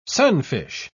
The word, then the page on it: sunfish: British English